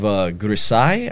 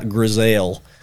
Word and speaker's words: Grisaille: Then we have Grisai, I have to imagine that's how that's pronounced. Sure. Definitely not Grisail.